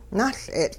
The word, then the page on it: nuL'e'tL': Hupa